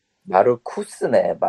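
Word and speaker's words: Markus: 마르쿠스네. 마르크스라고 읽으니까 씨 어디 붉은 형변 같잖아. 예, 마르쿠스.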